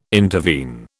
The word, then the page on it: intervene: British English